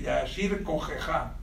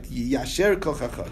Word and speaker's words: Yasher Koyach: Yiyasher Kachacha Shishibarta. That was the right thing to do. – Yasher Kachacha. – Right. That's the correct way to say Shkoyach. Right. Yiyasher Kachacha.